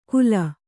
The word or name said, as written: ಕೂಲ